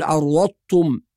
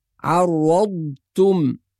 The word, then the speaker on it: عرضتم: الخطأ الأول في كلمة عرضتم، فبعض الناس يدغم الضاد في التاء وينطق الكلمة شبيهة بالطاء فيقول عرضتم. ونبهنا على هذه الملاحظة قبل ذلك في بعض الكلمات، لازم نظهر الضاد الساكنة وننتقل منها إلى التاء المضمومة بدون تكلف وبدون قلقلة، فنقول عرضتم، هكذا ننطق الكلمة بالصورة الصحيحة.